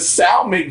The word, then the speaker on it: salmon: You don't pronounce the L in salmon. Salmon.